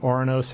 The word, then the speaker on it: Ouranos: Well, this is the seventh planet from the sun, so we're doing Ouranos and Uranus.